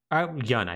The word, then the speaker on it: Jun: However, perhaps the most consequential fighter to enter this is a woman by the name of Jun Kazama. So, Yun, I believe, is actually how it's pronounced. My apologies.